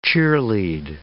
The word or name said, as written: cheerlead